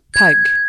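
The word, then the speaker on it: pygg: Cette argile s'appelle PEG, P-Y-G-G. À l'époque, on le prononce pug », Et puis la langue évolue et la prononciation de ce mot avec. Au XVIIIe siècle, l'argile se prononce pig, comme le cochon. P-I-G, ce qui donne l'idée aux artisans potiers de faire des récipients en forme de cochon.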